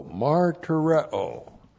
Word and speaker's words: martureo: M-A-R-T-U-R-E, long O, martyrao.